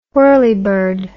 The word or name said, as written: whirleybird